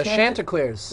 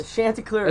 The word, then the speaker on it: Chanticleers: The Chanticleers. – The Chanticleers.